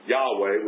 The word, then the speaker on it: Yahweh: Do not have the attributes that our God has, Yahweh, which is how his name is pronounced in the Old Testament, that, you know, so that is my take on these beings.